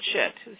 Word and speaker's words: Scheat: S-C-H-E-A-T, which is actually pronounced shit. It's spelled fixed star, S-H-I-T? – S-H-E-A-T. S-C-H-E-A-T.